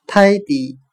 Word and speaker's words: tidy: 我们可以用谐音法来记忆这个单词 tidy，t i d y，它的发音很像汉语的泰迪，泰迪熊的泰迪。